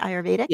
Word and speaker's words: Ayurvedic: Well, and we ended up having this whole conversation and I brought you up because, you know, the Ayurvedic, I can't say that right. – Yep. No, you said it right. Ayurvedic.